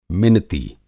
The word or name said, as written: मिनती